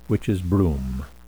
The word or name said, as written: witches broom